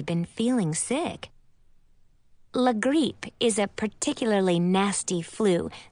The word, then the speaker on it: la grippe: It is pronounced la grippe, but sounds even nastier when someone like Grand-Mère or Madame Hélène says it, because they both roll their R's and pronounce the letter I like E-E, so it comes out sounding like la grippe.